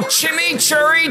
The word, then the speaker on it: chimichurri: Chimichurri. – That's how you pronounce it. Let it roll off your tongue.